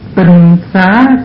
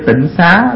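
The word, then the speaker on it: tinh xá (精舍): Chữ tinh mà sao cứ đọc là tịnh ha. Chữ này chữ tinh chứ đâu phải là chữ tịnh. Bởi vậy nên mình bây giờ kêu tịnh xá là một cái kêu sai.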